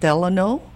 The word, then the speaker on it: Delano: And the Delano, is that the way to pronounce it? Delano, which is right by Mandalay Bay? – Yes, exactly.